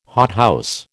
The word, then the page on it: hothouse: American English